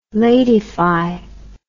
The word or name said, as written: ladyfy